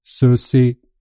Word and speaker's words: sushi: Och då uttalas det sushi. – Det är inget sje-ljud eller tje-ljud. Det är sushi.